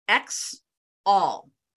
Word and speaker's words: XA: XA is actually pronounced X all. It's a term used in stocks and shares, which is something to do with ownership of your shares. So XAL is how the album is pronounced.